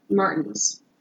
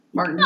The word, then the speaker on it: Martins: a social security letter addressed to Enrique Angles Martins. I feel like it should be Martinez, but it's just spelled like Martin with an S on the end. Martins. – Oh, okay. – Plural well, Martin. – Martins.